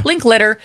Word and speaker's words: Linklater: Linklater, they've worked together before on Everybody Wants Some and Hitman.